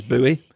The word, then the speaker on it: buoy: You seem quite vindicated that they've made Alan Cumming pronounce buoy the American way, which is buoy. – Yes, you always make fun of me for pronouncing buoy, buoy.